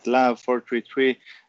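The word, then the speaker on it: Lahav 433: And you worked for the cybercrime unit LHAV 433. Am I saying that right? – Yes, you're pronouncing it right. LHAV 433.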